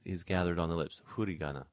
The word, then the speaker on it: furigana: The kanji characters, the little characters are called hurigana, which is, of course, written F-U-R-I-G-A-N-A, and that huri is the bilabial fricative.